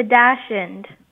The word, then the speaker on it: Dachshund: The Dachshund. – The Dachshund. And some pronounce it the Dachshund. The miniature Dachshund, the Dachshund. Perfect. You got it.